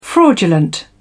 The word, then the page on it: fraudulent: British English